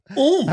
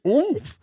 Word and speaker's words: Oumph: So Oomph. – How do they spell it? – O-U-M-P-H. Oomph.